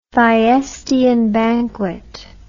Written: Thyestean banquet

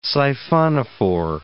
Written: siphonophore

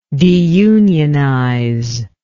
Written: deunionization

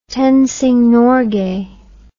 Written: Tenzing Norgay

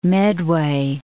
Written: Medway

